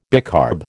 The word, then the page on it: bicarb: American English